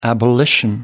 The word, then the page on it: abolition: British English